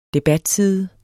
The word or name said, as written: debatside